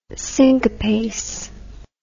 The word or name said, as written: cinquepace